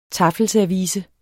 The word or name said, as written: taffelservice